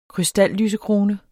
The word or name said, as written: krystallysekrone